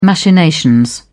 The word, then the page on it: machinations: British English